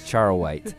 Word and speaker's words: charoite: The old charoite. Is that what it is? – Yes, you said it.